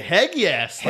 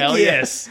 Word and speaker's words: Hegyes: Robert Heggies? – It's spelled H-E-G-Y-E-S. So yeah. I want to say Heggies. – Like, Heggies.